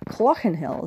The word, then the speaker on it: Clochnahill: and it concerns the farm of Clochnahill, or Clochnahill as it's pronounced locally.